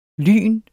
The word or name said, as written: lyn